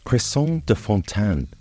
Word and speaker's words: Cresson de Fontaine: Watercress, or yellow cress as it is also known, is pronounced Cresson de Fontaine in French, and spelled Cresson, C-R-E-S-S-O-N, de, Fontaine. F-O-N-T-A-I-N-E.